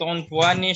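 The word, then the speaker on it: unfuan (عنفوان): Bacanya apa? Infawani apa unfawani? – Si uh, infawani Ustaz. – Dapat dari mana itu? – dapat dari sini. Di mana? Di mana? Dan di di kamus. – Sebentar, Sat. – Ya, bacanya unfuan ya.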